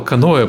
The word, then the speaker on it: Каноэ: Я правильно сказал каноэ, потому что на самом деле кану правильно э, у тебя по-английски записано? Каноэ, да, каноэ. все верно.